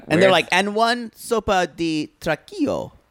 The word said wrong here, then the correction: tronquito: Or caldo what is it? I'm going to say it wrong. Tronquito. – Tronquito.